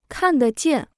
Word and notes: 看得见: kàn dé jiàn

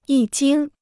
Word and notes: 一经: yī jīng

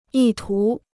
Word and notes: 意图: yì tú

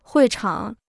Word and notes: 会场: huì chǎng

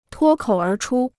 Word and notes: 脱口而出: tuō kǒu ér chū

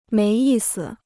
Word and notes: 没意思: méi yì si

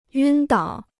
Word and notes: 晕倒: yūn dǎo

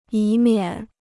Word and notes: 以免: yǐ miǎn

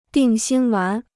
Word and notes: 定心丸: dìng xīn wán

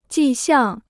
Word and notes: 迹象: jì xiàng

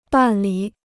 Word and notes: 办理: bàn lǐ

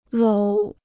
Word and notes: 搂: lǒu

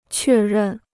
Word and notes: 确认: què rèn

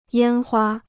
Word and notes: 烟花: yān huā

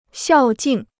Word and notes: 孝敬: xiào jìng

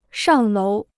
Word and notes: 上楼: shàng lóu

